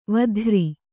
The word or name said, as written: ವೇತ್ರಿ